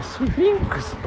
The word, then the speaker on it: 獅子女: 獅子女と書いてスフィンクスと読む